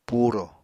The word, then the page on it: purò: apatani